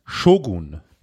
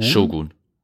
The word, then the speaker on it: Shogun: Das heißt Shogun, nicht Shogun. – Shogun. Okay. Also man kann es auf beide Arten und Weisen aussprechen. Ich meine, die japanische hat die Betonung auf dem O.